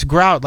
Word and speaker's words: Grout: I said, "It's Grout, like the cement between the tiles in your bathroom."